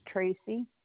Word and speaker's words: Tracy: Last name is Tracy, T-R-A-C-Y. Address is 14840 Bernard Road, Maquoketa. That's M-A-Q-U-O-K-E-T-A, Iowa, 52060.